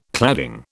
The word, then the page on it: cladding: British English